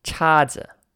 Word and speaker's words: 叉子: And we've got 叉子 for the last word here in this quick review. And that's CHA in the first tone and ZI in the no tone.